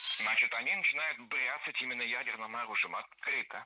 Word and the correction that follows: бряцать: Это слово «бряцать», потому что правильно говорить «брецать». Ударение в этом слове ставим на второй слог.